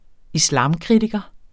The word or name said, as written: islamkritiker